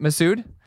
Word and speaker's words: Massoud: I'm probably butchering this name 100%, but we have Mena Massoud, who did Tom Clancy... Massoud? Oh, thank you.